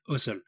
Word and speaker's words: Housel: c'est que le prochain épisode, on va parler du livre La psychologie de l'argent de Morgan Housel. Donc, c'est comme ça qu'on prononce.